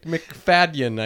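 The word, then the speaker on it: Macfadyen: We love McFagan. McFagan? – Did you say McFagan? – I apologize to all the McFagan clan for the mispronunciation. – McFadden, I think. – McFagan. – With a D.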